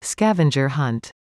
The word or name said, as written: scavenger hunt